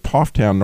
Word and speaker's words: Pfafftown: See, I live in the beautiful city of Pofftown, North Carolina, which is spelled P-F-A-F-F-T-O-W-N.